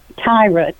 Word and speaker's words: Tyra: Am I saying that right? Syra? – Tyra, T-Y-R-A.